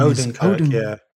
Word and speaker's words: Odenkirk: Odenkirk.